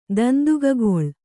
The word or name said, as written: ದಂದುಗಗೊಳ್